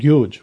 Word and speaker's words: yuj: Well, the word yoga comes, and my pronunciation is not perfect, but it comes from the word yuj, which is usually written in our language with Y-U-J.